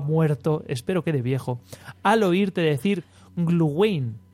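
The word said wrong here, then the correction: Glühwein: Sería Gluvain.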